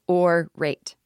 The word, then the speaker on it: Aurate: So the company is called Orate, and it's spelled A-U-R-A-T-E, but they told me it is pronounced Orate. So don't give me your Orate or whatever your fucking accent is. Orate. That's what they want you to say. A-U-R-A-T-E.